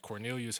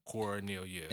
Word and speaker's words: Cornelius: It's no, nah, that shit pisses me off because they call me they call me Cornell. Like Cornelius has three syllables, which is my name. So Cornelius.